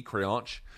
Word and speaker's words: creonte: It's spelled C-R-E-O-N-T-E, creonche.